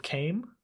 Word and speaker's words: Kaim: It's set around a character named Kaim, I believe is how it's pronounced. It's K-A-I-M, who is an immortal of sorts.